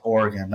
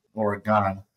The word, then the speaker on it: Oregon: I know it's pronounced Oregon, not Oregon.